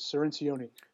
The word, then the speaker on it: Cirincione: By the way, just the last name is Cirincione. – Cirincione, okay. – It's hard to pronounce. I always have to explain, run it by everybody once or twice, so.